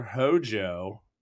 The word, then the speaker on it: Hojo: the biological son of Professor Hojo rhymes with Mojo Jojo, the villain from Powerpuff Girls, whose own name is derived from Ojo Caliente, an unincorporated community in Taos County, New Mexico, and Lucrecia Crescent.